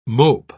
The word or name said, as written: mope